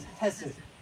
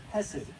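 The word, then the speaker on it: hesed (חֶסֶד): I want you to learn that Hebrew word, hesed. I don't know how it was originally pronounced. I mean, I can't make my H's sound like theirs.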